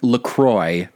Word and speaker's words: LaCroix: But the company is based out of Wisconsin, where the river that the plant is built on is called is pronounced Lacroix because it's an ah. American bastardization of a French word. So that is the official pronunciation is Lacroix.